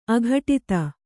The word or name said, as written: ಆಘಟಿತ